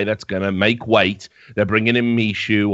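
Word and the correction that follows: MICHU: or Mihu, as apparently it's pronounced.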